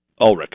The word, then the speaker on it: Ulrich: Ulrich.